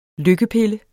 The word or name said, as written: lykkepille